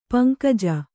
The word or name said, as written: ಪಂಕಜ